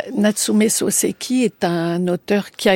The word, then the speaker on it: Natsume Soseki: Et on passe au début du XXe siècle avec Natsumi Soseki, si je prononce bien. – Natsume Soseki.